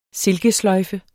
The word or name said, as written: silkesløjfe